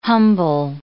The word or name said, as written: humble